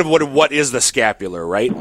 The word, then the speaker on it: scapular: My question, or if you could explain a little bit about the scapula, what you wear around your neck. is that, did I pronounce it right? – Yep, yes. Scapular. Yep.